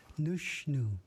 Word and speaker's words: Nushnu: In Ichishkin or Warm Springs, nose is said Nushnu.